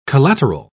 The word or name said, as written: collateral